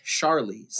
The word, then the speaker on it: Charlize: Did you say Charlize? – How is it pronounced? – Charlize. – Oh, Charlize? Okay.